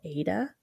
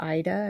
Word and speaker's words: Aida: So it's basically an X on the fabric and you work with a fabric called Aida or Ida. I've never really known about that pronunciation.